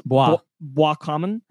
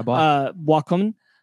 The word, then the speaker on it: Boiscommun: I'm pretty sure because it's French, it's Boiscommun. – There you go, Boiscommun.